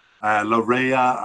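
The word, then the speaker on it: Laryea: Always working hard for Canada is Richie Laria. Larea, I've heard it pronounced. And yes, anyway, Richie Larea.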